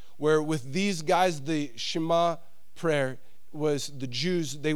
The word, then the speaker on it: Shema: Did I say it right, Marvin? Shema, thank you.